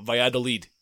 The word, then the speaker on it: Valladolid: I'm probably butchering this name, but Valladolid. – Oh, Valladolid.